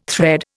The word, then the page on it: thread: Indian